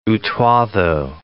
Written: Utuado

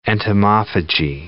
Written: entomophagy

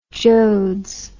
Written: joads

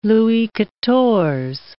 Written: Louis Quatorze

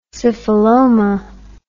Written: syphiloma

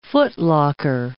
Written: footlocker

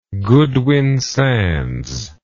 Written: Goodwin Sands

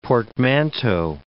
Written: portmanteau

